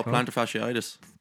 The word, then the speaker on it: plantar fasciitis: It's plantar fasciitis, I think. – Oh, plantar fasciitis. – That's how you pronounce it.